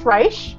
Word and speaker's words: Reich: Is it Reich? – It's Reich, but that's fine. Reich.